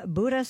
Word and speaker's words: Buda: Oh, no, he says Buddha. – Are you sure this isn't Buddha? The Buddha economic? – No, it's pronounced like beauty, so it's Buddha. B-U-D-A. It's so in Buda.